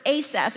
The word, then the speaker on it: Asaph: You're going to hear me say his name quite a few times today, so it's Asaph.